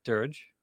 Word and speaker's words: dearg: D-E-A-R-G. How do you say that? – Dirge.